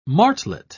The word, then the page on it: martlet: British English